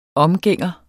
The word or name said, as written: omgænger